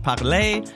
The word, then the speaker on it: Parler: And side note, I know Canadians, their brand name should be pronounced Parler, since it's obviously named after the French word for speak, but let's let them have their fun.